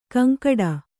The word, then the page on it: ಗೊಂಗಟೆ: goŋgaṭe